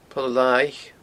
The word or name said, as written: Poll Lathaich